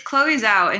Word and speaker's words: Chloé Zhao: How do you pronounce her name, by the way, just for the record? Because I think you spoke sure. It's Chloe Zhao, and she's incredible.